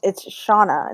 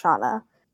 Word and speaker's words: Shania: It's pronounced Shauna, but it looks like Shania. But that's my name. – You sing, you dance. You know, Shania Twain. It's my, you know, spelled like Shania Twain, but it's Shauna.